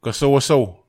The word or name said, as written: kasawasau